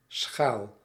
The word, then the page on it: bol: [bɔl]; France